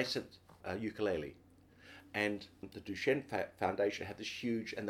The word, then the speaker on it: Duchenne: Duchenne, yeah.